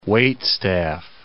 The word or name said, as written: waitstaff